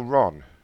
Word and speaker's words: Ron: You don't know how to spell Ron? R-O-N.